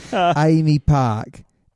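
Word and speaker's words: AAMI Park: Is it Amy Park? It's a beautiful... – AMI. – No, it's not. I couldn't spell it, mate. Amy Park.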